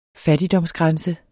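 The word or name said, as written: Fattigdomsgrænse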